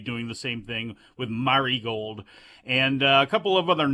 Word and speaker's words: Marigold: Rossi Ogawa's new group, Marigold, is apparently how it's pronounced. It's going to be Marigold. It's the way it goes.